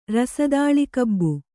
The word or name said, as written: ರಸದಾಳೆಕಬ್ಬು